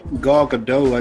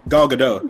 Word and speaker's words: Gal Gadot: Gal Gadot. – I thought it was Gal Gadot. – Yeah, I thought it was Gal Gadot. – It's a soft T.